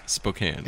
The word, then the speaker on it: Spokane: I mean, it's O-U, C-O-U. Well, coupon. – Then again. – Sorry, coupon. – The way you pronounce Spokane, I can never understand. – Spokane. – Yeah, it doesn't make sense. K-A-N-E is Kane.